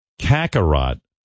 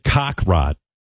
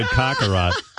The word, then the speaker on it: Kakarot: She mentioned a character from the show Kakarot. But she pronounced it cock-rot. Cock-a-rot.